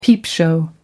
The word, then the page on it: peepshow: American English